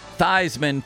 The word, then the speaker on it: Theismann: They changed, brilliantly, I might add, the PR department changed the pronunciation of his last name, Theismann, for the Heisman.